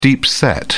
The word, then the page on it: deep set: British English